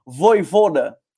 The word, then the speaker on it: Vojvoda: É, antes de eu, de eu trazer já a próxima pauta, e, e o Leão 19, 18 tricolor até mandou a pronúncia é Voivoda, Tá, não vou tentar falar o certinho da pronúncia, mas é, vai dar muito certo no Fortaleza, é um técnico promissor.